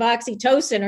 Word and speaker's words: oxytocin: Oxytocin. I'm glad you pronounced it because I'm reading it going, wait, how do you pronounce that? – Yeah. Not oxycodone.